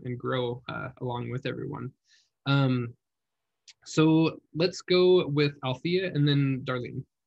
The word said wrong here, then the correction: Alethea: It's Alethea. – Alethea? Oh, yeah. I have been pronouncing it wrong this whole time.